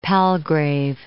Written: Palgrave

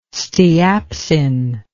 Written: steapsin

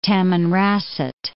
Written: Tamanrasset

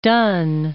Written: Dunne

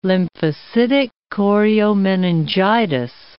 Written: lymphocytic choriomeningitis

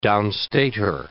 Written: downstater

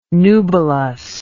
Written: nubilous

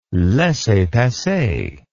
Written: laissez passer

